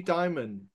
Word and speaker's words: Dimon: The favorite FUDster, Jamie Dimon. Is that who pronounced his name? I always forget. Yeah, yeah, so, yeah. Ever read it? – It's Demon.